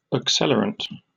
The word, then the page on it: accelerant: British English